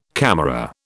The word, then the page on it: camera: British English